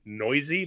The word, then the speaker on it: Neuse: Got back Sheldon Noisy. That's how you pronounce his name. N-E-U-S-E.